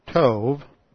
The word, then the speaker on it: tov: Well, this is why I've been saying all along and emphasizing that this Hebrew word, tov, T-O-V, it's a soft B, so it's pronounced like a V.